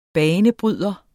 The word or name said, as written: banebryder